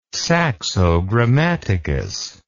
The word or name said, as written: saxogrammaticus